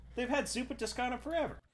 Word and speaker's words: Zuppa Toscana: You got some zuppa toscanas.